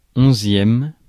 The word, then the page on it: onzième: France; IPA: /ɔ̃.zjɛm/